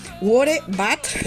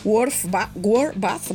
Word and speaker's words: War Bath: para el tema World Bath, que espero pronunciar bien, por favor. War Bath sería, War Bath, madre mía, mi inglés, qué maravilla.